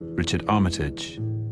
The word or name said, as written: Richard Armitage